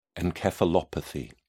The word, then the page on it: encephalopathy: British English